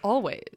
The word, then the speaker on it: Alvvays: This week, we will be reviewing the beautiful artist that you have just been listening to, which is our Canadian darlings, Always, spelled A-L-V-V-A-Y-S. Always, sometimes. – But pronounced Always.